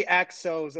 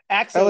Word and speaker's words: Axos: It's actually Axos. It's a long O. I asked him about it because I butchered it the whole time. Yeah, so Axos, like, Jim Duggan.